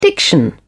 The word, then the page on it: diction: British English